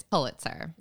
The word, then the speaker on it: Pulitzer: And I decided to go with poo. So that's what I'm doing. – And I'm saying pull. It's Pulitzer.